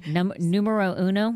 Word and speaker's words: numero uno: Numero uno? – That's right.